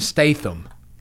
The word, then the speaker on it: Statham: I have a hard time saying his last name, so I got to sound it out. Because even when you say it right, it doesn't sound right. Jason Statham. – You did it.